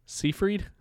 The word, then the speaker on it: Seyfried: Seyfried? – Yeah. – Yeah, Amanda Seyfried. – Amanda Seyfried as Karen. – No, it's Amanda Seyfried.